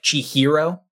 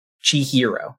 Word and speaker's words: Chihiro: I really hate how poorly they pronounced her name. they would say like Chihiro. – Mm-hmm. – It's not that hard to say. just Chihiro. It's not that hard. Chihiro. That's not right. Chi- Chihiro.